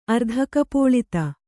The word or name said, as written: ಅರ್ಧಕಪೋಳಿತ